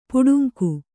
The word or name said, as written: ಪಟ್ಟಂಗ